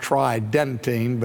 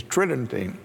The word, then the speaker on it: Tridentine: And reflections of that council are often discussed by the term Tridentine, which sounds like it would be pronounced Tridentine, but the normal pronunciation is Tridentine.